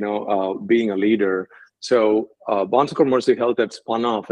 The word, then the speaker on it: Bon Secours: And what do you spend most of your time doing in your current role at Bon Secours Mercy Health, if I pronounced that correctly? – Yeah, you did. Bon Secours Mercy Health, again, is a pretty dynamic organization.